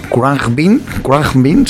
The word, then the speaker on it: Khruangbin: Una banda que se llama, es difícil pronunciar, Kruangbing, es K-H-R-U-A-N-G-B-I-N, Kruangbing sería, son de Houston, Texas.